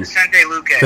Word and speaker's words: Vicente Luque: how do you say that name? – Vicente Luque. – Thank you.